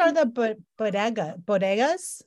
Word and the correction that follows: Bottega: Is that how you pronounce Bottega? That? Bottega.